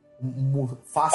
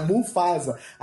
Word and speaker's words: Mufasa: Falei certo agora, sem ver? Falei. Mufasa. – Falou, não? – Mufasa. É Mufasa. Aí o cara que tava criticando o podcast vai criticar o Wikicast.